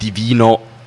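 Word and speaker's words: D'Vino: Und Divino ist geschrieben mit D-Apostroph Vino und Divino also normal so ausgesprochen heisst auf Italienisch göttlich.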